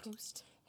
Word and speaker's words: Proust: Proust? – Proust. – That's how it's pronounced, yes.